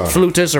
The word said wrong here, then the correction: flautas: or whatever the flutas. – Fuck. – Flautas. – He said it right.